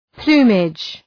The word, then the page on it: plumage: ‘plu:mıdʒ